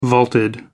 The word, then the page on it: vaulted: American English